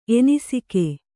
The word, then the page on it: ಎನಿಸಿಕೆ: enisike